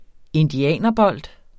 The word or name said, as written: indianerbold